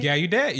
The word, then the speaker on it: Gallaudet: Gallaudet, yeah.